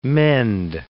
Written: mend

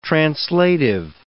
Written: translative

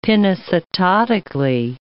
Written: pinocytotically

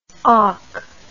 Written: aak

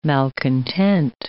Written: malcontent

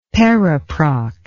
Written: periproct

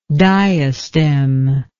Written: diastemic

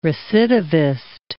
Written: recidivist

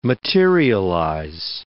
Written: materialize

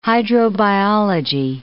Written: hydrobiology

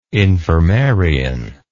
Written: infirmarian

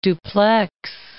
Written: Dupleix

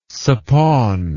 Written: suppawn

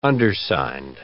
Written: undersigned

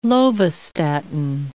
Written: lovastatin

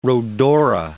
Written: rhodora

